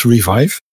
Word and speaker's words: Revive: Eh, je mocht het ook op het Frans uitspreken, maar ik houd het maar even op het Engels, Revive.